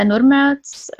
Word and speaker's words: Nurmeots: My name is Ave Nurmeots.